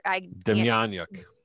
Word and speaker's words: Demjanjuk: Demjanjuk. – Demjanjuk.